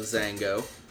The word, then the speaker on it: Xango: As it goes into the video, extolling the health benefits of Zango, and this is, again, spelled with an X, X-A-N-G-O. – I love that. – X-A-N-G-O.